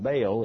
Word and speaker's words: Baal: And we've seen some of them named in particular, Baal, B-A-A-L, in the American South, routinely referred to as Baal. No one in the land would have recognized Baal. It was Baal. He pronounced both of the vowels.